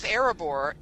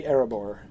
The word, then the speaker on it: Erebor: Now with Erebor, it is more air, like A-I-R. A-I-R, yes, air. Yeah. Yes, because that would be the long, the long E there. Yeah. So it's definitely not ear, because the E sound is associated with I, not with E.